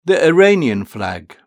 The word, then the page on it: the iranian flag: British English